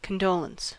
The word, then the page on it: condolence: American English